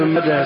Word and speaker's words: ماجه: يقول كلمة ابن ماجه اسم صاحب السنة تنقط وتنطق تاء في الوصل أو ها مع التوجيه، ماجه ومنده وداسة هيها، لا تنقط ولا تنطق، ماجه ومنده وداسة كلها بالهاء.